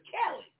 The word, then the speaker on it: Khaled: Where in Arabic is Khaled spelled K-H-A-L-E-D? It's supposed to be K-H-A-L-I-D. It's really Khalid. His name is Khaled. And you can't pronounce it that way. It's Khaled. It's Ka. Not Cal.